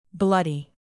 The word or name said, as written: bloody